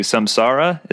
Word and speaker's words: samsara: I'm assuming you're you're referring to samsara.